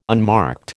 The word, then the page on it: unmarked: American English